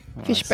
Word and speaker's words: Fishbein: It's spelled Fishbein. So it could be Fishbein. – Right, Fishbein to me.